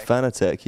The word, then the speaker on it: Fanatec: Is that how you pronounce it? Fanatec? – Fanatec, yep.